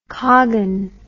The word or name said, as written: Kogan